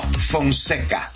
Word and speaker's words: Fonseca: All right, if you want to get connected, Julio Fonseca, am I saying it right? Francesca? – No, Fonseca.